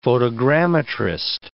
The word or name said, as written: photogrammetrist